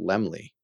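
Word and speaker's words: Laemmle: Showboat was a hit, and had the loan not been called for, that the Lammels would have been able to repay the debt and retain ownership of Universal. – I think it's pronounced Lemley. – Lemley, that's what I meant.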